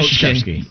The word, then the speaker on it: Krzyzewski: Mike Krzyzewski.